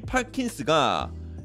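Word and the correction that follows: Perkins: S-O-N-N-Y 그 소니 할 때 소니랑 스펠링이 똑같습니다 퍼킨스 퍼킨스가 더 맞겠네요 파킨스보다 퍼킨스 S-O-N-N-Y요 N 두 개예요 크게 될 놈이다 이름 합격?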